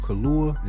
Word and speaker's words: Kaluuya: Speaking of good actor too, before we get out of here, man, my man from Daniel Kaluuya, is that how you say his name? – Yeah.